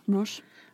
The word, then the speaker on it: Desroches: Enter Madeleine and Catherine Desroches. I think I'm saying that right. R-O-C-H-E-S. – Yeah. – Roche. – Roche.